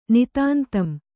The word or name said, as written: ನಿತಾಂತಂ